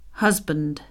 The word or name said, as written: husband